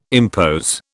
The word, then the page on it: impose: British English